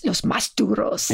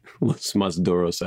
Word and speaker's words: Los Más Duros: You got to do it there's a performative aspect to the Los Más Duros, I imagine. You have to have the pronunciation. – Los Más Duros. – You have to roll some R's in there or something.